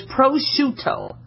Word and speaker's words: prosciutto: Okay, like prosciutto. Okay? A lot of times you guys are calling it prosciutto, all right? It's an Italian dry cured ham, okay? Think of shoe and toe, so it's prosciutto, all right?